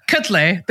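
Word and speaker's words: Quetelet: Oh, it's been a minute since I've practiced that pronunciation. There we go. Kuttle.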